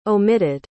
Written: omitted

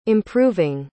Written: improving

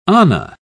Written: ana

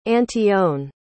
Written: antione

